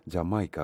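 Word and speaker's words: D'yer Mak'er: Πάμε στη δεκαετία του 70 για τους Led Zeppelin, Dire Maker, Jamaica όπως προφέρεται κανονικά, Did You Make Her ουσιαστικά, στην διάλεκτο του Τζαμαϊκανή, μια προσπάθεια για ρέγγε και ντάμπ εκεί στις αρχές δεκαετίας του 70, τον ήχο της Τζαμάικα από τους Led Zeppelin.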